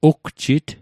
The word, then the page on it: ugjit: Mi'gmaq